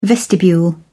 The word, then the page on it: vestibule: British English